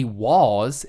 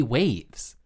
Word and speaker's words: Wavves: But Wavs, W-A-V-V-E-S, is not supposed to be Waws. It's supposed to be Waves.